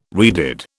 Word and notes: redid: British English

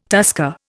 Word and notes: tusker: Indian

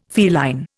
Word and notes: feline: Indian

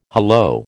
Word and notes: hallo: American English

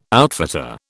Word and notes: outfitter: British English

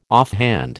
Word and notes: offhand: American English

